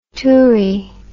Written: toorie

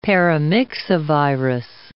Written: paramyxovirus

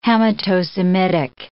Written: Hamito-Semitic

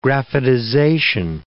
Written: graphitization

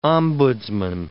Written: ombudsman

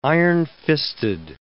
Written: ironfisted

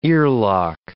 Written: earlock

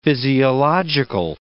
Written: physiological

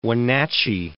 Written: Wenatchee

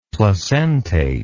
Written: placentate

